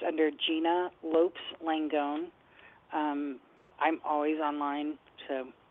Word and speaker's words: Lopes: Oh, see, we did say it wrong. We said Lopez instead of Lopes. Okay. – Lopes, yeah. – Correct. – Yeah, I'm Cape Verdean, not Spanish.